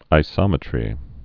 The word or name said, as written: isometry